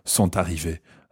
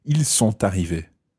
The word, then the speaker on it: sont arrivés: Yeah, or les garçons sont arrivés. Okay, in that case, you probably would pronounce the T of sont arrivés. Ils sont arrivés.